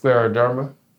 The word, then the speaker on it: scleroderma: So it's pronounced scleroderma.